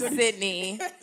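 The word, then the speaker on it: Sydnée: As and always, I am Marie, M-A-R-I-E. – I'm Sydney. – Spell it. – S-Y-D-N-E-E, with apostrophe over the E.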